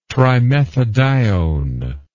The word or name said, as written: trimethadione